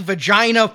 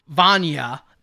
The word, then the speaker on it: Vajna: Vagina Presents. – What? – Didn't you guys notice Andrew G. V-A-J-N-A? – Vajna.